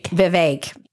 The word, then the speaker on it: Vivek: I always feel like I mispronounce his name because I heard someone say it rhymes with cake. – Vivek.